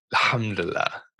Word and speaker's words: Alhamdulillah: Alhamdulillah.